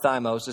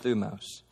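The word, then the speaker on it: thumos (θυμός): The first Greek word is thumos. It looks like thymos. It's pronounced thumos.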